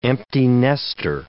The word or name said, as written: empty nester